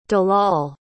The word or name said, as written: dalal